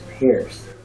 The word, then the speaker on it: Peirce: So the topic of this meetup, hosted by Rami, was titled The Logic of Charles Sanders Peirce. It's spelled P-E-I-R-C-E, Peirce.